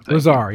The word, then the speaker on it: Lazar: Lazar, yeah, there you go. – Yeah. So like I said lazy. Sorry, yeah. I was mispronouncing it.